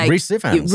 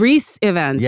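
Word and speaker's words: Rhys Ifans: Oh, Reese Evans. – Reese Evans, yeah.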